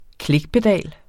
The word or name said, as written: klikpedal